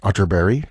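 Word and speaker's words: Atterberg: This is from the Swedish composer Kurt Otterberg, spelled Otterberg, but pronounced Otterberg, just in case you were Googling.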